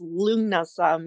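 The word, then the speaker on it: Lughnasadh: And I'm like, I love saying it, Lunasa. But it's basically, Lu is it within that. So L-U-G-H, pronounced Lu.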